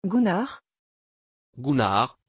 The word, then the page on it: gunar: French